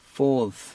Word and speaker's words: fourth: Fourth. – 아랫 입술을 무는 상태에서 시작을 하세요. 발음을.